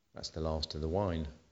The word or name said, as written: last wine